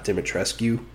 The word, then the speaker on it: Dimitrescu: So, her name is pronounced something like that. It's like Dimitrescu. It's not Dimitrescu, like we all thought.